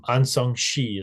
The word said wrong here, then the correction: Aung San Suu Kyi: Aung San Suu Kyi.